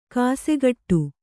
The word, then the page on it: ಗಸಿಗಟ್ಟು: kannada